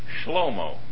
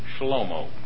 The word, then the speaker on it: Shlomo: You know, the word Solomon is a nice fancy way to say Shlomo. That's the way it would be pronounced in Israel today, Shlomo.